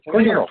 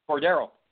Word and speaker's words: Cordero: Cordero. – Franchi. Pardon me? – Cordero.